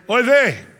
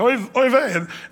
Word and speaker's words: oy vey: They'll say, oy vey. That's how they say it today, oy vey.